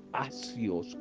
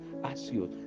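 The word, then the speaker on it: asios (ἄξιος): se dice asios con X, asios, de donde se deriva nuestra palabra castellana axial, axiología, valores.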